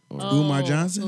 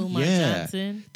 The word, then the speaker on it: Umar Johnson: I know the last part is Mar. The first part is either Umar or Omar. – Umar Johnson? – Umar Johnson.